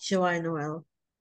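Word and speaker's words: Joyeux Noël: In the movie, Joex Noel. – Joie Noel. – Joie Noel. See, I'm already being corrected already.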